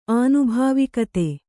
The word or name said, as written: ಆನುಭಾವಿಕತೆ